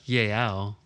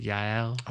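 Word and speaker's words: Jael: I would argue that JL, I feel like I'm still pronouncing her name. I got to get that book. – Probably. – Oh, wait, it's a J, so it'd be like Yael, like Yael.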